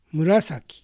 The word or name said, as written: Murasaki